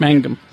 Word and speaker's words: Mangum: Tracy, I want to say Mungum, if I'm pronouncing that right. – Mangum. – M-U-N-G-U-N? – Yeah, yeah.